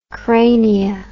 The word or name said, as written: crania